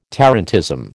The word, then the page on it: tarantism: American English